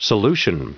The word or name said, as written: solution